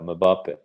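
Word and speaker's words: Mbappé: A mislim, meni je ta večer gledao sam to Mbappe-a, jel, kako se ne znam kako se izgovara, Mbappe. – Mbappe, da.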